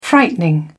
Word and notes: frightening: British English

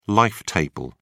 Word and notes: life table: British English

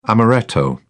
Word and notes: amaretto: British English

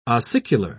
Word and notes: ossicular: British English

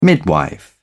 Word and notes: midwife: British English

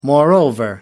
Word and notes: moreover: American English

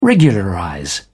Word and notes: regularize: British English

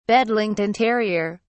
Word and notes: bedlington terrier: British English

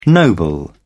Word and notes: noble: British English

